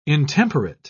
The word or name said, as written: intemperate